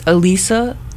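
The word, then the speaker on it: Alysa: But she said, like, people pronounce it all different ways because it's usually, you know, it looks like Alyssa, but it's Elisa because there's only one S.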